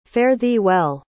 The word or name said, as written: fare-thee-well